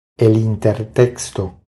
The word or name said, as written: El intertexto